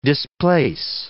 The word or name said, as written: displace